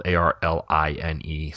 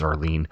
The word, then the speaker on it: Arline: It's spelled A R L I N E. So it looks like mm-hmm. Arline, but I'm assuming you're gonna you're gonna pronounce it Arlene mm-hmm.